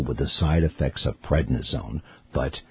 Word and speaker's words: prednisone: People often mispronounce it pregnisone, but it's prednisone, which is a steroid that is like a sledgehammer to the flare-up.